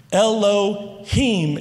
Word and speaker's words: Elohim: It's Elohim. Elohim is how you say that.